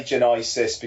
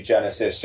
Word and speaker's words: Genisys: And by the way, I say Genisys because that's pretty much how it's spelled. I know it's supposed to be Genesis, just in case anybody's wondering.